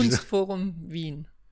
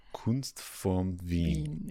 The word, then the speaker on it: Kunstforum Wien: Please pronounce the name of this institution. – Kunstforum Wien. – Kunstforum Wien. – Yes.